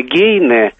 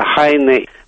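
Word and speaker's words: Heine: Немецкое Гейне вместо Хайне.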